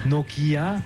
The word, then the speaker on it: Nokia: Mais il ne faut pas accentuer la prononciation de chaque lettre. – Non. – Nokia. – Non. On pourrait, mais c'est bon aussi Nokia.